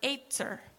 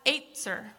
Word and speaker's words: ezer: The word helper in Hebrew is the word etzer. Can you say that? Etzer. You just learned Hebrew. Amazing. So that's spelled E-Z-E-R, etzer.